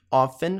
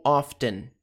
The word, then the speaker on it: often: Você pode pronunciar very often, often, como se não tivesse o T, ou often, often.